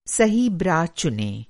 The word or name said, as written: ब्रा